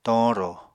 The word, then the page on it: toñrò: Apatani